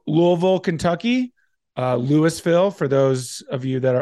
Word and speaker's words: Louisville: Don't ever say that to someone from Kentucky, specifically from Louisville. It's basically pronounced Lou, like L-E-W, Vol, V-U-L, Louisville. That's how you say it and make it sound like you're a local.